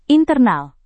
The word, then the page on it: internal: indonesian